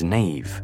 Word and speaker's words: Niamh: And yes, I know the traditional pronunciation of Niamh is Neve.